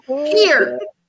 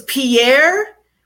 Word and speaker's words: Pierre: Okay, it's called Pier, but it's spelled in such a way that people from other states are like, "Your capital is Pierre." And I'm like, "No, nah, it's Pier." Peering out of a window because I'm creeping. Peer. That's what it's called. That's how it's pronounced.